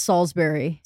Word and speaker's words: Salisbury: I know we say that wrong. We've said it before. – Salisbury. I think I want to say it's Salisbury.